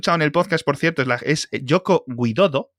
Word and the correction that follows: Joko Widodo: Sí, la verdad es como, que Como, como Yoko pero con J, Joko. Joko Widodo.